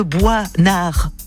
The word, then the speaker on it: Boisnard: Louise Adélaïde Boisnard, ça s'écrit B-O-I-S, N-A-R-D. – Donc on dit pas Boisnard, mais il y a un S. Mais d'où il vient ce S? – Mais peut-être de la forêt.